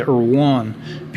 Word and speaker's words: Erwan: I say Erwan, because that's easier for people to pronounce.